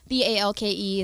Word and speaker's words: Balke: Yeah, Balke is the last name. It's a little weird. So, yeah, is that... – it's Norwegian. – I'm always afraid that I'm going to say it wrong. – Everyone does, so I just kind of take it. I just go with it, because I get a lot of Balk, of course, and a lot of Blake, even.